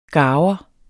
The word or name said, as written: garver